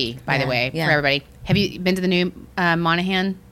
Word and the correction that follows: Moynihan: Station? I don't know how to pronounce it. Moynihan.